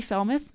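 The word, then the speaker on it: Fellmeth: So I'm just going to quickly mention my Twitter handle is at Holly Felmuth, my name. That's spelled H-O-L-L-Y, F as in Frank, E-L-L-M-E-T-H, all one word.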